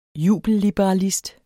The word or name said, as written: jubelliberalist